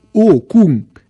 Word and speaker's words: Ocom: Ocom, prononcé Ocum, exclamation sudiste.